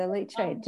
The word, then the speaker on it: leachate: It's not the worm tea, but it just comes out of the The leachate.